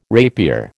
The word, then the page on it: rapier: American English